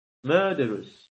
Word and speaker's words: murderous: Murderous. Aquí. Esta E. Pueden leerlo como una schwa. Porque se leen siempre como una especie de medio vocal. Nunca llegas a pronunciar la vocal completa, sino que te la comes.